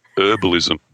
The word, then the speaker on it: herbalism: What about you and your and your herbalism? It's got a oh, H on the front of it. Where's the H gone? it's herbalism. We took the H. when speaking, a H is silent.